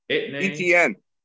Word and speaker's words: Etienne: ETN.